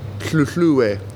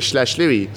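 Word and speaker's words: Hluhluwe: We went to Tlutlwe. Good pronunciation, eh? And as opposed to everyone else says Schlashliwi.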